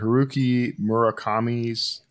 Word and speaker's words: Haruki Murakami: Haruki Murakami's uh, novelist as Good a job.